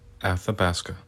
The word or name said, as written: Athabasca